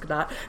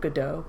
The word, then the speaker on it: Gadot: So, overall impression, without going into too many details, is that I felt like Gal Gadot, which I learned it is pronounced Gadot. I recently saw an article saying that instead of Godot, but good to know.